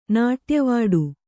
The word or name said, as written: ನಾಟ್ಯವಾಡು